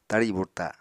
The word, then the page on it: tari burta: Apatani